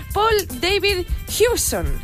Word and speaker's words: Paul David Hewson: Hace unos añicos nació un señor con el nombre Paul David Hewson, aunque nosotros siempre le conocimos por otro nombre.